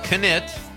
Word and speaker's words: Knit: Craig Anthony Knit, I'm saying that right, the K is not silent,